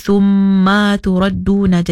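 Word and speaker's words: summa turadduna (ثُمَّ تُرَدُّونَ): Di situ hati-hati ada tempat yang si ada mat ataupun si ada panjang. Jadi kita boleh bacanya jangan dipanjanglah. Contohnya macam di situ kalimah thummaturadduna. Tadi mungkin si perasan maya ada terpanjang sikit. Jadi summa turadduna. Jadi yasya, hati-hati, kena pendekkan.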